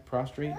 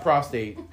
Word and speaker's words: prostrate: Did I say that right? Prostrate? – Yes. Mm-hmm. – It's not prostate.